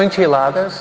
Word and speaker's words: enchiladas: Tortillas, enchifladas, ¿cómo? O enchiladas, sí. Siempre lo digo mal.